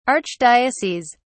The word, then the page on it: archdioceses: British English